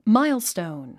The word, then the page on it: milestone: máilstòun; マイゥストーン